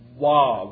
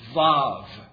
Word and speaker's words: Vav: And it's actually pronounced, if you're filling this in, it's pronounced Vav. It's pronounced, the W's are V's. So it's Vav.